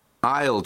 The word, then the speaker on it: aisle: Это слово I'll читается. Там буква S есть.